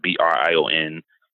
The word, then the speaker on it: Brian: So my middle name is Brian, but it's spelled Brian, but it's Brion.